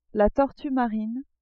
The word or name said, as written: tortue marine